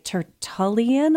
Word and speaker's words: Tertullian: Tertullian. I hope I'm saying that right. – That's right.